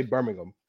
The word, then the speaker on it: Birmingham: So, but because of you, now I say Birmingham. So, thank you for that. – It's a silent H.